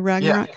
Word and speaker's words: Ragnarok: Is that how you pronounce it too? Ragnarok? – Yeah.